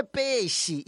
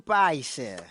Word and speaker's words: peixe: Não é peixe, e sim paixa. Repita comigo, paixa.